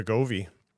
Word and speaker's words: Wegovy: That segment beat on against the estimates, and then it has the I don't know how to pronounce it. Wigony. – I think it's called. I think it's actually Wegovy.